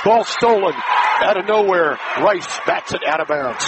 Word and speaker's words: Reiss: Spelled R-E-I-S-S. Pronounced Rice.